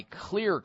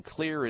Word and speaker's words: Xlear: Sparks Candies by Clear Corporation, and Clear is spelled X-L-E-A-R. The X is for xylitol.